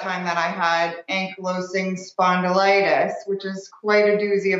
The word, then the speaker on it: spondylitis: Spondylitis, yeah.